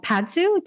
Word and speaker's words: Padzu: Before that, he was the property manager and founder of Padzu.